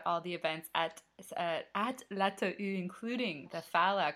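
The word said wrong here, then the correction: Falla: coming up, the Faya coming up this weekend. Don't pronounce the double L.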